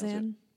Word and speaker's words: Xan: I don't know how you pronounce Xan.